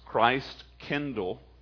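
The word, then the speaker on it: Christkindl: Actually, it's a transliteration from the Old German. The Old German is pronounced Christ Kindle.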